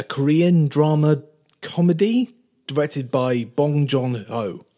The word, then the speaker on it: Bong Joon Ho: Bong Joon Ho. – Bong Joon Ho. There you go. – Yeah. – That's how you're supposed to pronounce it.